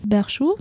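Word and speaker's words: Berchoux: Von dem französischen Schriftsteller Joseph Berchou, wenn ich ihn so richtig ausspreche. – Ja, hast du, das ist mein Buddy.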